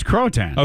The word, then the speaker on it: Croatan: It's Croatan. – Okay, I've heard people say it a bunch of different ways. – And I don't care. It's Croatan. – Okay, thank you. Well, Croatan Island, new findings on Croatan Island are adding weight to a long-debated theory about the fate of the lost colony of Roanoke.